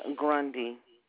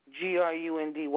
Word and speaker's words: Grundy: Felicia Grundy. – And that was 30349? – Yes. – Will you spell that last name? – Grundy.